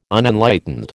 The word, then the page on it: Unenlightened: American English